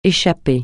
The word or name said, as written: échapper